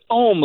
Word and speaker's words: Om: Is that how you pronounce it? Om? – It's Om, like you're meditating, like Om.